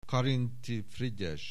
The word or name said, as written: Karinthy, Frigyes